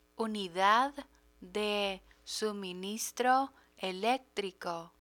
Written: unidad de suministro electrico

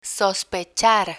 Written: sospechar

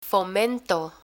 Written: fomento

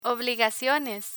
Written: obligaciones

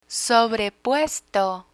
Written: sobrepuesto

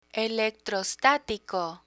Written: electrostatico

